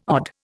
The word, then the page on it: odd: Indian